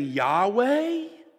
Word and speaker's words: Yahweh: And today we would pronounce that word in two different ways depending on which century you're from. We would either say that that name is the name Yahweh or Jehovah.